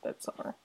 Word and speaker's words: Midsommar: It's like, it's stylized as Midsommar, but it's pronounced Midsommar.